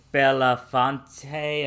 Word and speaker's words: Belafonte: Yeah, and we also lost another legend recently, Harry Belafonte.